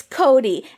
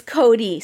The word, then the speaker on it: Coty: And also, the guy, it's Cody. And I know it's confusing because it's spelled C-O-T-Y, but it's Cody, sir.